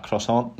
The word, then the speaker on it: croissant: Even a fresh croissant on holiday, gonna get butchered for the way I pronounce that croissant. There we go, bit bit more French.